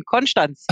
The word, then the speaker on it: Konstanz: Wir sind zwar ein ST, aber wir haben ein Sch drin. Konstanz.